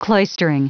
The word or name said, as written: cloistering